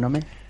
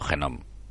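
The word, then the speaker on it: GNOME: ¿Cuánto tiempo llevas con Nome? Mm, Habéis visto audiencia lo bien que lo pronuncia, yo digo Genome. Genome o Nome. Bueno, pues si es como New, bueno, pues con, con Genome Llevo yo confieso, yo confieso, con hashtag, yo confieso que llevo con Genom eh, tres semanas y sigo vivo.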